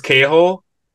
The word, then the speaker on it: K-Hole: Is it pronounced K-Hole? – Yeah. – Yeah. – All right. I don't know if they want like... – The K isn't silent.